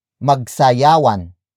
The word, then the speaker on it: magsayawan: For example, magsayawan, magsayawan, magsayawan.